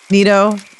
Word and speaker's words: Nitto: A lot of money, and I'm looking into some tires, still researching it, you know, and a lot of people use the Nitto, is that how you say it? – Nitto, they're mud grapplers, but I don't know, I'm still researching that, so start a savings plan.